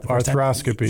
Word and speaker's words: arthroscopy: Did I say it right? – Yep, arthroscopy.